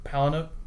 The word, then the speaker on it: Palahniuk: I'm going to say it wrong so you can fix it, okay? Chuck Palahniuk? – I think that's right.